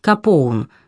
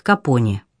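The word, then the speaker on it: Capone: а свою фамилию стал произносить на английский манер Капоун, что не мешало всем прочим читать ее как Капони.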